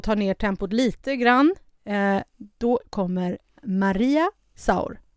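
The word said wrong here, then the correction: Sur: Så är det Sor.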